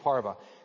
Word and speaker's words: parve: It looks like parve, but it's pronounced parva.